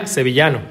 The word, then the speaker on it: Sevillano: Sevillano. Did I say that right? – Yeah, Sevillano.